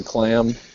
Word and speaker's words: Klamm: Uh, hi, my name is Jason Klamm. I just said I just said it the way most people pronounce it. My name's Jason Klamm.